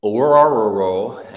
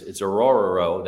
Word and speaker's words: Ororo: Rook Ororo, and I know that sounds like Scooby-Doo, but it's Ororo. That is his name. That's how you pronounce it. I looked it up.